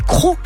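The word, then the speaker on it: Crots: C'est marrant, vous dites les Crocs, mais c'est Crocs tout court, la commune de Crocs. D'ailleurs, ça s'écrit Crotz, mais on dit Crocs.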